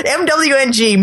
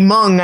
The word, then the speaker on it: Mwng: Mwng, Mung, I think is how you pronounce it.